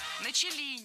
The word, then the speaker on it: начали: Никто особо на эти вещи не обратил внимания, хотя с того момента начали раскрываться довольно там серьезные процессы. – Начали неправильно. Должно быть «начали». Ударение в этом слове падает на первый слог.